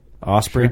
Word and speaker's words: Osprey: I went in there and bought a Osprey. Is that how you say it? Osprey pack. – Yeah, yep.